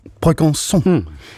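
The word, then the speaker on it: Brégançon: Jo, hvordan udtaler man navnet på den franske præsidents sommerresidens? – Brugonson.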